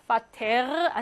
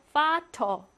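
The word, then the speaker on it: Vater: 어 단어의 맨 끝에 이렇게 있을 때 파테르 아니고 파터 발음하고요.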